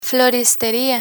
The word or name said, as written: floristeria